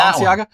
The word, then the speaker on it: Balenciaga: but Balenciaga? Yeah, Balenciaga.